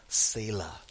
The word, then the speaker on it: Selah: And if by chance you are wondering how to pronounce that last word that Grace read, it's pronounced Selah.